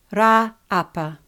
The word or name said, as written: Raaapa